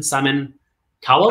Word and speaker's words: Cowell: Um, America's Got Talent, and then you've got Simon Simon Cowell, Cowell.